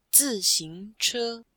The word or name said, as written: zi4xing2che1